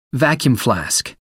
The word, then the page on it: vacuum-flask: American English